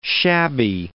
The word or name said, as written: shabby